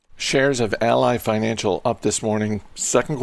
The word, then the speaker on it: Ally: I believe it's Ally because their television commercials, I think, pronounce it that way.